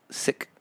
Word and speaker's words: Sikh: Kundalini actually has a kind of a Sikh, S-I-K-H, probably should be pronounced Sikh, Sikh, but Sikh sounds like sickness, mm-hmm. so I say Sikh.